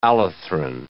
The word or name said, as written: allethrin